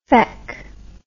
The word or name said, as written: feck